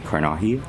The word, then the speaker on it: Carnaje: You can just search at Danny Carnahy.